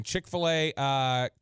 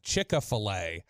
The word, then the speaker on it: Chick-fil-A: 469, Chick-fil-A, correct. Was Chick-a-fil-A, how my southern butt says it. Chick-a-fil-A instead of Chick-fil-A.